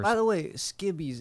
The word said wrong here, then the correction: skivvies: Is that how you say it? Or is it skivvies? – Skivvies. – Skivvies, yeah. – Skivvies. Okay, okay. Yeah, skivvies.